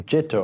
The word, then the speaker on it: citta: Again, I'll use the Pali term citta, C-I-T-T-A, but it's pronounced citta, and that means our mind.